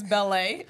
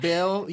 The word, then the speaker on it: Belle: It's Bellet. – So it's technically Bell. You did pronounce it right. So it's spelled B-E-L-L-E, but like in France it's Bell, but like we produce music as well.